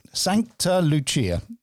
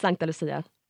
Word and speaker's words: Sankta Lucia: It's obviously, it's written down for me, and it actually pronounced the way I would. Sankta Lucia. But that's actually wrong. Can you say it again? – I mean, in Swedish, it's Sankta Lucia.